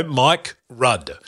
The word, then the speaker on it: Rudd: That's a good way to pronounce it, because it could sound like Mike Crud. – No, Mike Rudd.